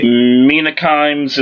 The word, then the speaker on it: Mina Kimes: Mina Kimes is how you say it.